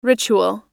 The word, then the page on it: ritual: American English